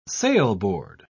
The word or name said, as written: sailboard